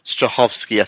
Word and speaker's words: Strahovski: And when you talk sex appeal, this show is fucking loaded when there's guest stars, whatever, but just the main star, Yvonne Strahovski. I think I've said her name right. Yeah.